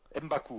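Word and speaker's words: M'Baku: et son chef Ubaku. Décidément, c'est compliqué prononcer tout ça. Mbaku, voilà. Mbaku, je l'avais fusionné avec, Okabi, qui vient défier la, Panthère Noire.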